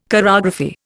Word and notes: chorography: Indian

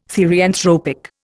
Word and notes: therianthropic: Indian

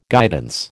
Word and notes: guidance: American English

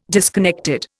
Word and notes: disconnected: Indian

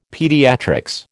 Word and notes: pediatrics: American English

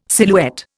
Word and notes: silhouette: Indian

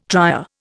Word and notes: trier: Indian

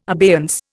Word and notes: abeyance: Indian

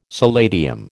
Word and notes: solatium: American English

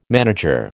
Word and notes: manager: American English

